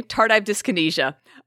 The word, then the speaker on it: tardive dyskinesia: That is up for debate, Gabe, because as I looked across the internet, there's a few different ways people pronounce this. Tardive dyskinesia, tardive dyskinesia, tardive dyskinesia. Today, we're mainly going to be saying tardive dyskinesia.